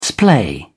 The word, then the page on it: splay: British English